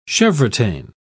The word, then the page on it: chevrotain: British English